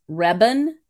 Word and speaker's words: Rebbun: I actually, my maiden name was Rebun, okay? R-E-B-B-U-N.